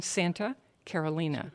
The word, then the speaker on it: Carolina: It looks like Carolina to the rest of us, but it is in the Spanish pronunciation. Santa Carolina.